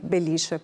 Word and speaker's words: Belice: Proprio per questo motivo a volte si utilizza l'accento sulla I, Belice, proprio per sottolinearlo.